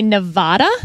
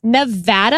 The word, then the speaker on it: Nevada: Because a lot of politicians would say Nevada. – Wrong. – And that is wrong. – It is Nevada. And I had to train myself to say Nevapple so I could pronounce the A right.